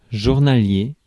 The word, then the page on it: journalier: France; [ʒuʁnalje]